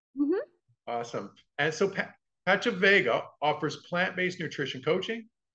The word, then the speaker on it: Pachavega: and founder of Pachavega. Did I pronounce that right? Yeah. Oh, perfect.